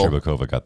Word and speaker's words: Shcherbakova: Shcherbakova got the gold, right? That's how you pronounce that. – Yes, yeah, yes.